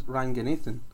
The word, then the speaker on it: Ranganathan: Ramesh Trakanathan. – Well, doesn't work. – How? – His last name is Ranganathan.